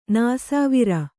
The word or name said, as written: ನಶ್ವರ